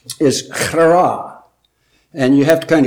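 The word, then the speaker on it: chara (χαρά): Chara. It looks like an X in the Greek writing, but it's a letter is chi. And it's chi alpha rho alpha. But the word is pronounced chara.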